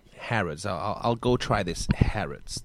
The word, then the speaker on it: Harrods: Oh, it's pronounced Harrods. – Yes, it is. Okay.